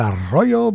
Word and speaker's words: Arroyo: This is Father Mario Arroyo. Arroyo, if you can speak English, if you can roll your R's, it's Arroyo, but it's otherwise just do the R.